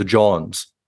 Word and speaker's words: Jawns: So the band is spelled J-A-W-N-S. – So the Johns.